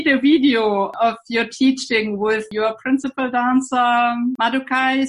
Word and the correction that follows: Madoka: Sugai. – Yes, Madoka, yeah. – I don't know whether I pronounce well her name. – Yes, Madoka.